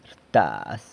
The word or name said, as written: rtaz